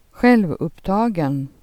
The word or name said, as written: taget